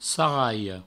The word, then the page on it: sarralha: sarraio